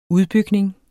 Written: udbygning

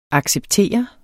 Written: ACCEPTERENDE

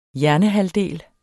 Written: hjernehalvdel

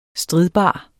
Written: stridbar

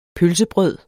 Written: pølsebrød

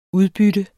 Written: udbytte

